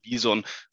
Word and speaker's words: BISon: Also vorab, du hast es auf jeden Fall richtig ausgesprochen. Es ist tatsächlich Bison für Nicht Berater. BIS, e BIS.on.